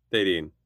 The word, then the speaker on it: Thadine: Did I say that right? – Sure. – Come on. – Who? – Come on. How is it? – Thadine.